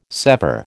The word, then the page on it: sever: American English